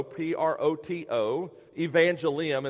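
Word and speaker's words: protoevangelium: That's spelled P-R-O-T-O, proto. The word proto means first, like a prototype, right? So proto, P-R-O-T-O, evangelium, it's almost like the word evangelist, E-V-A-N-G-E-L-I-U-M. So it's one word, proto-evangelium. It's P-R-O-T-O, E-V-A-N-G-E-L-I-U-M.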